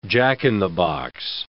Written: jack-in-the-box

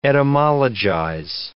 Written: etymologize

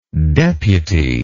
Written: deputyship

